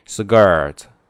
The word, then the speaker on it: skirt: 好，我们再来看女性的从上面开始看啊，上面那个 blouse 就是女衬衫啊，女士的衬衫我们可以叫 blouse 然后呢那个丝巾叫做 scarf 短裙叫做 skirt 对，这个大家在读的时候那个 k 要读成 g 变音 skirt 然后脚上穿的呢是高跟鞋，所以呢叫high heels 或者说不要 high，直接说 heels， heels 也是高跟鞋啊。